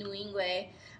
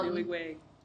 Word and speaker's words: Nwigwe: Like, and that's what I was listening to this interview on 97.9 The Box with Toby Nwigwe. I'm saying his last name wrong. But Nwigwe. – Thank you.